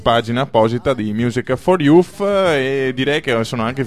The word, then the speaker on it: Youth: La pronuncia per i non anglofoni, avvisa Favotto, è YOUTH.